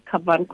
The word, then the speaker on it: Kavan: I don't know if I'm pronouncing it correctly. Is it Kavan Consultants? – Yes, it is Kavan Consultants.